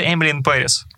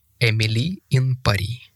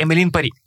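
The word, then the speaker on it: Emily in Paris: Повертається Емілін Періс, другий сезон. А ти знаєш, правильно читається Емілі Ін Парі.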